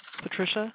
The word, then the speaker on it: Patricia: Patricia. – Okay, could you spell that for me? – P-A-T-R-I-C-I-A.